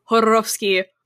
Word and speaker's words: Jodorowsky: I read tarot, and I have the Jodorowsky. I'm not going to do it like that the whole time, but it's not a hard J. That's like the actual pronunciation.